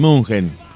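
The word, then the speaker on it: München: Pero desde entonces, Bayern de Munchen, que es como se pronuncia en alemán,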